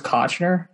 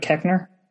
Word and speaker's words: Koechner: Did you guys know that David Koechner's last name isn't pronounced Kochner, but Koechner? – Yes.